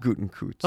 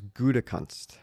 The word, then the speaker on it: Gutekunst: It is not Gutenkunst. – Oh, no. – It is Gutekunst.